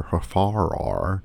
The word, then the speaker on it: Hfuhruhur: Now our longer elevator pitch is that world-renowned brain surgeon Dr. Hafarar, and that's not a mispronunciation, that's the way they pronounce it in the movie,